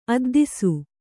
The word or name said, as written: ಅದ್ದಿಸು